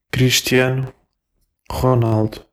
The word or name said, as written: Cristiano Ronaldo